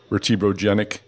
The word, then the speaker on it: vertebrogenic: Am I saying that right? Pain. I read it and then I'm like, oh shoot, I didn't understand how to say it. For vertebrogenic or genic? Or am I mangling it entirely? – No, you are saying it perfectly.